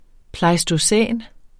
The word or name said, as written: pleistocæn